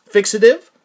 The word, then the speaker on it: FXTV: And on July 3rd, you released two different collections, one being a rarities album titled PS4 and a new EP titled FXTV, which you wrote in the liner notes is pronounced fixative.